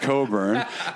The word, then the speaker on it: Cockburn: Spelled Cockburn, pronounced Cockburn.